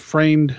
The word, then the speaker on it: Freund: And what's interesting about this film, about The Mummy, it's directed by Carl, I think it's Freund is how it's pronounced. Freund, friend, starts with an F.